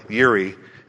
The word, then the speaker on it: Jireh: He is Jehovah Jireh or Yiri is a proper pronunciation, but Jehovah Jireh, our provider, what it literally means is he sees you.